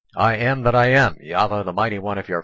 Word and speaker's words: Yahweh: The Tetragrammaton and proper Antioch Hebrew scrolls spells YHVH, pronounced Yahweh.